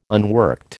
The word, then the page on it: unworked: American English